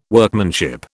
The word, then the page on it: Workmanship: British English